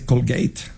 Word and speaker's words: Colgate: Entonces, bueno, no se dice Colgate, se dice Colgate.